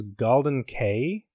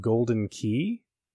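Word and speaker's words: Galdin Quay: It's the I always thought it was Galdin Cay, spelled GALDINQUAY from Final Fantasy 15. But when I was looking some information up about it, apparently it's pronounced Golden Key.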